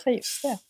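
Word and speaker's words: Cajuste: Är det Kajuste det uttalas? Ja, Kajuste.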